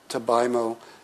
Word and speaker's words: Tabaimo: It was less pure, there was an artist from James Cohan, Tabaimo. Am I pronouncing her name right? Where's Arthur? – Yeah, that's correct.